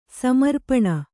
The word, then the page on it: ಸಮರ್ಪಣೆ: samarpaṇe